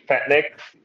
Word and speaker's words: Fet Nixx: You are indeed Fet Nixx.